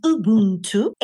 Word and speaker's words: Ubuntu: Well, it's an African principle. It's pronounced Ubuntu.